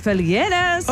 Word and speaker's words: Felgueras: Espera, ¿era con E o con I tu apellido? – Es con E, Maca Hansen. – Pero lo dije bien, lo dije bien. – Dijiste Kevin Felgueras, que ese es mi nombre.